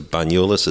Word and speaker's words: Banyoles: It's an old looking setup here on the outskirts of Banyoles.